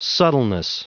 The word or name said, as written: subtleness